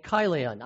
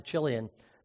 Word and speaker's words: Chilion: Now, they had two boys, two sons, Mahlon and Chilion. I used to pronounce it Chilion, but my wife corrected me. It's Chilion. And which is consistent with the Hebrew too.